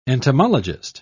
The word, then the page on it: entomologist: British English